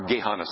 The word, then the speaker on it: Gehenna: Okay, one is the word for hell, Gehenna, or Gehenna sometimes, but Gehenna is usually how it's pronounced.